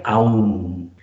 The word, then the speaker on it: Om: Primero, aprender a pronunciar el OM, que se pronuncia, como decía, AUM.